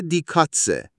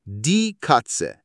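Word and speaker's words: die Katze: و گربه میشه دی کاتسه. دی کاتسه.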